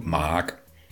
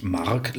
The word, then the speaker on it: Maak: Es heißt einfach nur Mark. – Okay, und kommt woher? – Wie Tomatenmark. – Genau, fast so wie Tomatenmark.